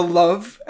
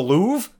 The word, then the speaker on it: Louvre: And then, Breaker has this one line, they're going somewhere near this place known as the Love. And I'm like, well, his backstory is he's Moroccan. They speak French in Morocco. – Yeah. And so he mispronounces the Louvre, and he's scrolling through like a tablet of major Parisian tourist attractions.